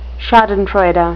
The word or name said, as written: schadenfreude